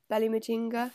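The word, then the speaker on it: Belly Mujinga: I think I'm saying that right. I looked it up, Belly Majinga.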